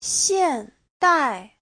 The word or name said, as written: xian4dai4